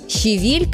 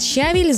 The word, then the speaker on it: щавель: Правильное ударение на второй слог. При этом вариант «щавель» звучит очень часто, настолько, что кажется нормой. Но в литературном языке это все-таки ошибка, хоть и привычная. Интересно, что в разных формах слова ударение переезжает дальше. «Щавеля», «щавелю», «ощавеле». А вот в прилагательном возвращается обратно «щавелевый суп». И получается, что вроде слово знакомое с детства, а вот ударение в нем не самое очевидное. Запомнить можно просто. Вот такая карусель. Не дают мне есть щавель.